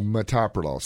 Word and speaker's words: metoprolol: Metoprolol. So it can be pronounced metoprolol. That is the correct.